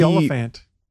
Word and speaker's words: Olyphant: I can never say his name right? – But Timothy Oliphant. – Yeah, Oliphant.